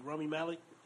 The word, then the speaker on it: Rami Malek: Homeboy from Bohemian Rhapsody, Rami Malek. Am I saying his name right? – Yeah, you are.